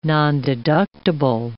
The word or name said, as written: nondeductible